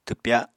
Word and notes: twpya: apatani

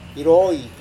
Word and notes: iroi: Serewen

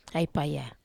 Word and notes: ai paya: Wamesa

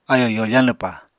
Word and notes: ayò yoofañ lwpa: apatani

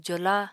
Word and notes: jola: apatani

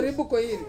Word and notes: ribu koiri: Ansus

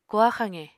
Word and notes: koha xaqe: Apatani

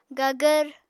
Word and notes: gagvr: apatani

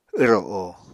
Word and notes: wro o: Apatani

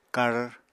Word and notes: kar: Apatani

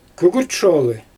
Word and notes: kügürt sholI: Xyzyl

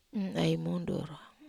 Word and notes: ai mundur: Wamesa

